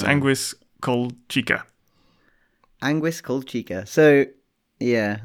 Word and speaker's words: Anguis: Uh, how do you say it? Ang- Angius? Ang- Ang- Anguis? Ang- I can't. I can't. – Oh read, yeah, Anguis.